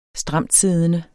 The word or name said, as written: stramtsiddende